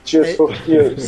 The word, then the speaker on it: Tears for Fears: Poxa, eu tô falando errado esse nome toda vez. – Tears for Fears. – Isso, é ele emulando Tears for Fears.